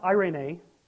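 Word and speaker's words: eirene: And the word there, having made peace, in verse 14, for he himself is our peace, that is the Greek word, it's pronounced irene, which is like irony, similar.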